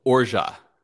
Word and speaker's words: orgeat: Or it's technically pronounced orja.